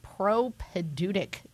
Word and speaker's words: propaedeutic: So new seminarians this year are in what is now known as the propedeutic year. Did I pronounce that correctly? Can you explain what this is? – That's right. And if you spell it correctly, we'll give you a gold star. – P-R-O-P-A-E-D-E-U-T-I-C.